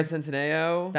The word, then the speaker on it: Noah Centineo: Yeah, Noah Centineo.